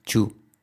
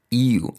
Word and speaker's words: you: Pronunciación, no decir chu, ok, you. A veces sí que el you va a sonar como chu siempre que vaya delante una T.